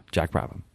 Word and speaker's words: Brabham: You pronounce the ham. Brabham. Brabham. Not Brabham is probably an American way of saying it. Brabham. – Brabham. – Brabham. – Okay. – Jack Brabham.